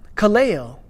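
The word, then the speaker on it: kaleo (καλέω): But in looking at this word deeper, you see that it comes from this root word, kaleo, which means to call. But kaleo is, is, is this like the root of a vast network of, of different powerful New Testament words. The word call, kaleo, this has at least 10 other words derived from it that I counted.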